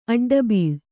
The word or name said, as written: ಅಡ್ಡಬೀಳ್